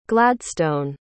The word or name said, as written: gladstone